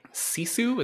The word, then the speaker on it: Sisu: Okay, speaking of action, you also had a chance to see Sisu.